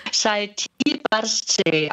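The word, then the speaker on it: Šaltibarščiai: Fast. Man spricht sie Šaltibarčičai aus.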